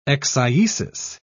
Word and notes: eccyesis: British English

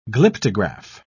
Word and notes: glyptograph: British English

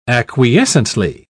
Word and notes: acquiescently: British English

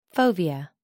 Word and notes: fovea: American English

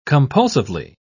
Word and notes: compulsively: British English

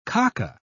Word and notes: kaka: British English

